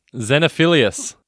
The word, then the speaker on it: Xenophilius: As Xenophilus Lovegood. Did I say that right? – Yes, Xenophilius. – That's the one. I was close.